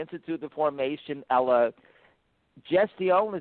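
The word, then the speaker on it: Institut de Formation à la Gestion: and I'm going to pronounce it wrong, Francois, the Institute de Formation à la Gestion, is that correct? – This is correct.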